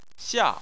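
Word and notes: xia: tone 4

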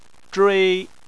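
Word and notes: zhui: tone 1

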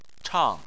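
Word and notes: chang4: tone 4

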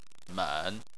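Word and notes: men: tone 3

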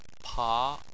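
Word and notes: pa2: tone 2

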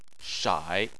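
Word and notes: shai: tone 3

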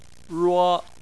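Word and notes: ruo: tone 1

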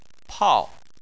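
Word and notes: pao4: tone 4

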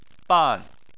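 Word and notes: ban: tone 4

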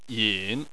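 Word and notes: yin: tone 3

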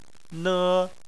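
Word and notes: ne: tone 1

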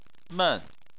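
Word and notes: men: tone 4

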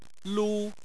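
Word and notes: lu: tone 1

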